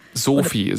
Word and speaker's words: SOOFI: SOFI, S-O-O-F-I, haben wir gelernt, wird ausgesprochen, ein bisschen wie der Name, aber nicht genau.